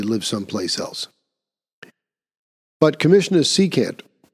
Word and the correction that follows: Keechant Sewell: um, am I pronouncing her name correctly? No, Keechant Sewell. Sewell is her last name.